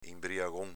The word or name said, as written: inbriagon